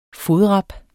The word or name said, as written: fodrap